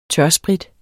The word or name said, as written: tørsprit